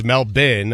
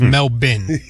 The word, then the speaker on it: Melbourne: Well, I thought it was Melbourne when we started today, but it's Melbourne, okay. And that is courtesy of Noah Eagle. – Yeah, so Melbourne. The Australian pronunciation, Sal, is Melbourne.